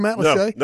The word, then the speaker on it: Matlacha: If you were to look at it on a map, it's spelled Matt Lacha. – Okay. – M-A-T-L-A-C-H-A.